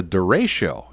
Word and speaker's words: derecho: So I've got to ask you, what in the world is a derecho, and am I pronouncing that properly? – Well, I think you are pronouncing it correctly.